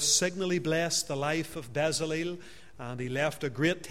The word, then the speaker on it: Bezalel: Bezalel, or Bezaliel, as some people would pronounce it.